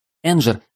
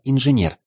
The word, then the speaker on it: инженер: Энджер – это название его профессии, исковерканное название. На самом деле произносить надо «инженер».